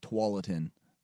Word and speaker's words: Tualatin: Like Tualatin. That is probably not how it was pronounced. – Not at all. – By the Native Americans at all. No. It's like, no, that's how it's said now.